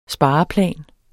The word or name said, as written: spareplan